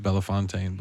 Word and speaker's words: Bellefontaine: Is when in 1980 in Bell Fountain, we say Bell Fountain. You could pronounce it Bellefontaine, but I think colloquially it's Bell Fountain.